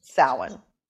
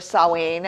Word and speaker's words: Samhain: Don't spell it. Samhain. Or Samhain, as my Scottish ancestors would say. Because in Scotland, the Gaelic.